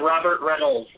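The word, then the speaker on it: Robert Rennells: At Robert Reynolds. My last name is spelled funny. It's R-E-N-N-E-L-L-S. – Do it again. – R-E-N-N-E-L-L-S.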